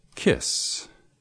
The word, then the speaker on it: kiss: It's because in Spanish, you don't have a short I sound, the sound of I in kiss or pitch.